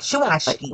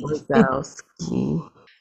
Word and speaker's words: Wazowski: So Mamba's gonna be Sully, and Kobe's gonna be Mike Schwaschke. – Wazowski. – No, you're not saying it right. – That is the way to say it.